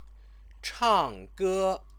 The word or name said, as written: changge